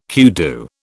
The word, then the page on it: kudu: British English